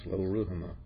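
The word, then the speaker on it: Lo-ruhamah: And the Lord said to him, "Name her Loruhamah, for I will no longer have compassion on the house of Israel, that I would ever forgive them." – All right, you really pronounce Loruhamah very well.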